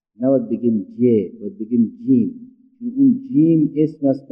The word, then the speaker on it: جیم: ولی وقتی خواستیم تلفظ بکنیم نمیگیم جه، بگیم جیم. نمیگیم به، میگیم با.